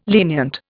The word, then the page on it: lenient: Indian